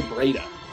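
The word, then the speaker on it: Breida: Oh, no, see, you call him Beretta because you can't even pronounce, you can't say Breda. – Breda? I can't. – Breda. – You know, I can say Breda, I've just been calling him Beretta.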